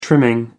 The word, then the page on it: trimming: American English